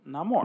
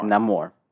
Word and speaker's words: Namor: And I'm calling him Namor. – Namor.